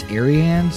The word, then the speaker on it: Arianne: But anyways, I sort of like it, it's spelled like it would be like Brienne, but it's Arianne.